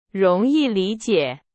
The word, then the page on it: 容易: Chinese